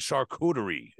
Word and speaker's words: Charcuterie: From Heavy Hole Podcast, and I'm here with Justin and Jordan from Philadelphia-based score grind band Charcuterie, if I pronounced that right this time. – Yes. Yeah, that's it.